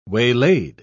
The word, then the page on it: waylaid: British English